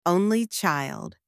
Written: only child

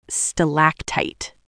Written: stalactite

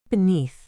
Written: beneath

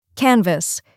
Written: canvas

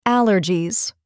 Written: allergies

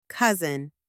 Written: cousin

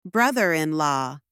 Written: brother-in-law

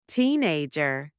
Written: teenager

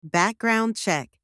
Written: background check

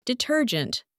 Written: detergent

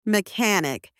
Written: mechanic

